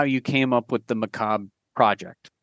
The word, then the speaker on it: macabre: I'm one of the people who doesn't pronounce the R Macabre. I'm just saying. Not everybody. – I believe okay, anyway. That's the correct way to say it. – Well, it's the French way of saying it.